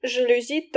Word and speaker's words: жалюзи: жалюзи только ударение на «и».